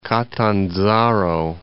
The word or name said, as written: Catanzaro